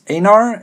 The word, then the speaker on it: Einar: My name is Einar. It's pronounced with an A, but it's spelled with an E-I.